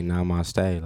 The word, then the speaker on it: namaste: I just don't know what point of the day you got to say namaste. Like, is that how you pronounce that? – Namaste, yeah.